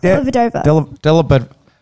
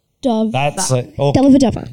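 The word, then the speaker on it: Dellavedova: Delavadova. – Just Matthew Alphabet. No, you got it right. – Delavadova.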